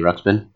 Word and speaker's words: Ruxpin: Can you pronounce that again? – Teddy Ruxpin. – Okay. You got it right that time.